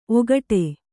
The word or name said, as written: ಔಕಾಟ